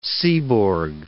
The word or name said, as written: Seaborg